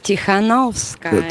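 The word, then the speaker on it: Cichanouska: Ciechanouska, tak się wymawia. – Cichanowska. – Cichanowska? – Tak. – Cichanowska. Tichanowska.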